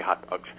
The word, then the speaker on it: Haute Dogs: And then the other thing is on Facebook, Hot Dogs, and it's spelled H-A-U-T-E, like Haute, but it's Haute Dogs, but we say Hot Dogs.